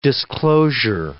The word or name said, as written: disclosure